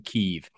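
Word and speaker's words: Kyiv: Kiev. It is pronounced Kiev, by the way, not Kiev.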